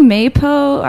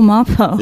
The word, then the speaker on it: Mapo: Josh was like, do you know Maypo? I want to do a Maypo squash. I'm like, what are you talking about? He's like, how can you not know what I'm talking about? I'm like, oh, Mapo.